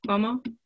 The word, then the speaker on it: Cuomo: I don't really know, but I know that this morning I just heard Governor Cuomo in, did I say his name right? Cuomo? Yeah.